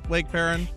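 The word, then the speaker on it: Lake Paran: Am I saying that right, Lake Perrin? – Yes, you are. You are saying it right.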